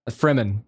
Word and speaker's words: Fremen: Fremen.